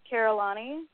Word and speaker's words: Carollani: Yeah, I'm pretty active on Twitter, Facebook, and Instagram, and I have a pretty unique name, Carollani.